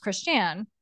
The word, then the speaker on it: Christiane: And so they said it's pronounced Christian, but on paper it's Christian. But my mom apparently had no idea of this whole thing, which is a conversation between my dad my whole life, apparently.